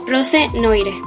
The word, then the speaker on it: Rose Noire: La primera banda de la que hablaré hoy se llama Rose Noir, aunque se escribe como Rose Noire.